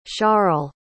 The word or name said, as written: charle